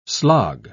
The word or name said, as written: slog